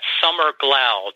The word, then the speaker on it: Summer Glau: But this is a major feather in, our cap, in my opinion, and that's Summer Glau.